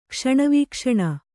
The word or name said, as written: ಕ್ಷಣವೀಕ್ಷಣ